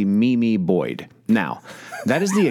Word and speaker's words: Meme: His dog's name is Mimi. However, I thought, because it is spelled M-E-M-E Boyd, I thought it was Meme Boyd.